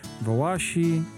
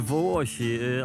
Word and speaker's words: Wołosi: Wołosi, Wołasi, jak to się właściwie powinno wymawiać? – Po prostu Wołosi,